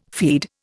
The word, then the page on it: feed: Indian